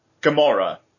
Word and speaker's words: Gamora: Gamora.